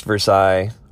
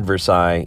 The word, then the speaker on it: Versailles: Yes, spelled like Versailles, but not pronounced Versailles, Versailles. And if you ever said Versailles in Indiana, people would look at you either mystified or very loudly correct you that it's Versailles, not Versailles.